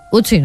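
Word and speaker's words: Ötzi: Otzi, creo que se llamaba, un nombre muy, muy noruego. – Utsi, ¿no? – Utsi, pues sí, Utsi.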